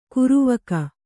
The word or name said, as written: ಕರಿವಕ್ಕಿ